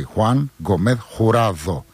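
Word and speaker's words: Juan Gómez-Jurado: Ένα εξαιρετικό βιβλίο από τις εκδόσεις Κλειδάριθμος, είναι η Ουλή του Χουάν Γκομέθ Χουράδο. Προσέξτε για την προφορά, έτσι. Χουάν Γκομέθ Χουράδο.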